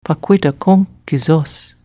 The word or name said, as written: Pahquitaqunk kesos